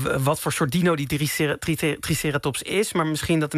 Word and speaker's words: Triceratops: Ja, het is dus officieel zeggen we Triceratops. – Triceratops. – Het is net die klemtoon die, zeg maar, op de andere plek terechtkomt.